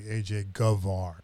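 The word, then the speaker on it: Gevaerd: It's actually AJ Govar, so it begins with a G, not a B.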